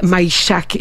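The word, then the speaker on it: Majchrzak: Mais vous les avez vu, tu sais, le jeune garçon, là, ça a été autographié par le joueur Camille Maïchak. C'est comme ça qu'on prononce son nom, là.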